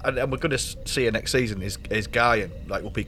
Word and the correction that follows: Guinan: Guinan. – The, sorry, Guinan. Did I say it wrong?